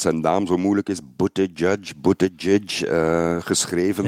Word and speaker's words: Buttigieg: Ja, ik heb gezien dat hij zelfs t-shirts verkoopt met de fonetische eh, uitspraak voor zijn naam. Boot Edge Edge is dat dan. Ja, voilà, Buttigieg.